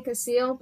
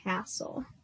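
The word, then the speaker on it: Cassill: I actually used to think his name was Landon Cassil, but then I realized actually it's pronounced Landon Castle. So I have been corrected.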